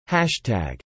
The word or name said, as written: hashtag